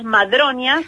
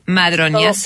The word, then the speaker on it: Madroñas: es Taller Las Madroñas. – Ah, Las Madroñas, sí, perdón, lo dije mal. Las Madroñas. – No, no hay problema. Es como madre y doña. – Madroñas.